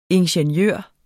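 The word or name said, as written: INGENIØRER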